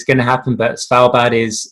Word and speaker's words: Svalbard: Svalbard.